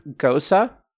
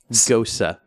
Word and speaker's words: Gose: Can you spell that for our listeners who want to Google it? – Yeah. G-O-S-E. – Gosa. – Gosa. So a gosa is like a lighter alcohol beer.